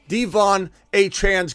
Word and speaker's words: De'Von Achane: No one's saying De'Von Achane's going to go out, and that's how you say his name. He corrected the record, and I went and made sure I watched the footage or watched the release of it. It's not even De'Von. It's De'Von Achane. He wanted everybody to correct both ways to pronounce both his first and last name.